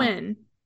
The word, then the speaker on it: Nguyen: Nguyen.